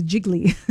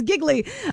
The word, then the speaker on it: Giggly: Yeah, obviously I was mispronouncing Giggly. I thought it was Jiggly, but it's Giggly.